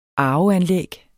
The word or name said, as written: arveanlæg